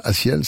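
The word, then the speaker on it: A6EL: Si vous le cherchez, si vous cherchez des informations, Asiel, ça s'écrit A, le chiffre 6 et E-L, Asiel, avec une chanson qui s'appelle Soleil.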